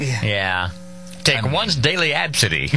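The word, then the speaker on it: Abcde: Pronounced Absidy. – Absidy. – No. – Absidy. Come on.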